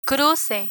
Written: cruce

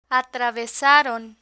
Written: atravesaron

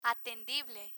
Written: atendible

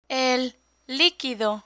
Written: el liquido

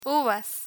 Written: uvas

